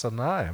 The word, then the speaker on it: Sinai: And that first time was at the foot of the mountain that is, yes, it's pronounced Sinai. I'm so glad to hear that. You know, English-speaking people make the two-syllable word three. They say Mount Sinai or something like that. But the A-I at the end of the Hebrew word is actually a diphthong, Sinai.